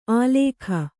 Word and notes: ಆಲೇಖ: kannada

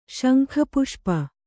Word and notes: ಶಂಖಪುಷ್ಪಿ: śankha puṣpi

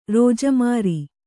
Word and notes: ರೋಜಮಾರಿ: rōja māri

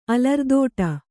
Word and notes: ಅಲರ್ದೋಟ: alardōṭa

